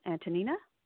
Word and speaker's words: Antanina: Antanina, are you there? – Yep.